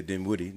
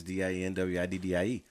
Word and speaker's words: Dinwiddie: And like I said, people sometimes mispronounce and say Dinwiddie. No, it's not Dinwiddie. It's Dinwiddie.